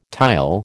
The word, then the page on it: tile: American English